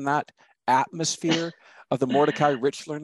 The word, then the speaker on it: Richler: I'm never sure how to pronounce the novelist's name, Richler or Rickler? – Mordecai Richler.